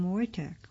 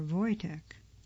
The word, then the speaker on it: Wojtek: During World War II, Polish soldiers bought and adopted a Syrian brown bear cub, naming him Wojtek, or Wojtek. It's spelled W-O-J-T-E-K.